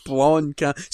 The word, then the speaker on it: Blomkamp: No, not Bonk Camp. Blonk. – Blomkamp.